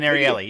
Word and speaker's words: Ariely: And I can't tell you, you'll appreciate the Dan Ierley, you know, the subscription. – Oh, Dan Ariely.